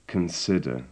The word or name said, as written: consider